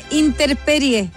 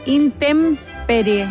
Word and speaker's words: intemperie: Ah, no se dice interperie. – ¿Cómo se dice? – Se dice intemperie.